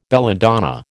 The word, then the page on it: belladonna: American English